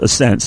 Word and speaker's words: ASNT: A-S-N-T. For, like in excess, kind of, for a multitude of reasons, the name, of course, is still pronounced Ascent.